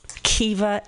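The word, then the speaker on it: Siobhan: It's like, do you know that Kiva is spelled C-I-O-B-A-N? That's Kiva, yeah.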